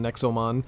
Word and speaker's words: Nexomon: And I'm just looking at it on the site here, this Nezomon, or however it's pronounced, Nexomon.